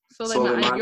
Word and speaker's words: Soleimani: Soleimani.